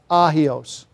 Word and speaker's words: hagios: The term that is used is hagios. H-A-G-I-O-S. It's actually pronounced ahios because of the silent H. And really, the G is not very, very strong.